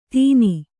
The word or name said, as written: ತೀನಿ